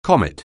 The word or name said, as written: comet